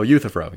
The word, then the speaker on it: Euthyphro: I think that I'm on board with the, I can never pronounce it, Euthyphro? – Oh, Euthyphro, yeah.